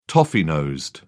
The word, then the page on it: toffee-nosed: British English